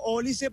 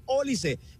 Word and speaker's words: Olise: Y yo le digo Olise porque el propio Fabio Martín me mostró un video donde este futbolista pronuncia así su apellido, Olise. Y ahora me sorprende escucharlo a Fabio decir Olise, Olise a cada rato. Pero bueno, esta es Olise según el propio futbolista.